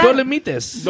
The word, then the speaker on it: Dolomites: Dolomites.